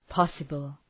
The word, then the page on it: possible: ‘pɒsəbəl